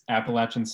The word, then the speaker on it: Appalachian: Yes, it's Appalachian State. It is in the Appalachian Mountains in Appalachia. If you pronounce that soft CH, you're incorrect. Please never speak to me.